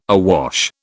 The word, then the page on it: awash: British English